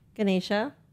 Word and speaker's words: Ganesha: During Diwali, people pray to Lord Ganesh to expect what in return? Wealth, happiness, or good luck? – To Lord Ganesha? – Yes. I'm sorry. I mispronounced it. – Ganesha. – Ganesha.